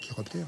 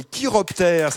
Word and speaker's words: chiroptères: Les chiroptères, hein? – Oui, je crois que c'est ça. Vas-y, vas-y. – Les chiroptères. – L'ordre des chiroptères, ça s'écrit C-H-I. Et ça se prononce bien chiroptère.